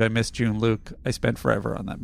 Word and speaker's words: Jean-Luc: I think if you had said Jean-Luc, I would have gotten it. – Oh, I pronounced it wrong. It wasn't French enough for you?